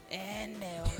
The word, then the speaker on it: Enel: Enel.